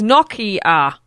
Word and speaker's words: Nokia: The correct pronunciation of Nokia is Nokia. Unfortunately, the American CEO, Stephen Elop, doesn't say it correctly.